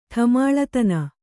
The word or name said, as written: ಠಮಾಳತನ